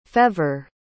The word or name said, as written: fèvre